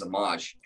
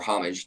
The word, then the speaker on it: homage: which obviously pays homage or homage, depending on where you're from in the world, how you pronounce that word, but to Nikola Tesla, which is a beautiful thing because his story is absolutely inspiring and amazing.